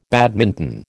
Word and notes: badminton: American English

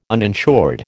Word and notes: uninsured: American English

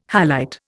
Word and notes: halite: Indian

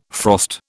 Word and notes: frost: British English

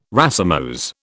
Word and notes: racemose: British English

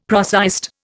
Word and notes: prosaist: Indian

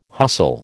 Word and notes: hustle: American English

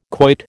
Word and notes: quoit: American English

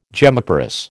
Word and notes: gemmiparous: American English

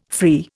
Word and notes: free: Indian

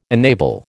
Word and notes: enable: American English

